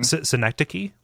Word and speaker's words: Synecdoche: Synecdoche, like the literary device.